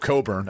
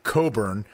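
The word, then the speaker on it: Cockburn: Coburn. Again, Coburn, Coffey Coburn, not how it's spelled.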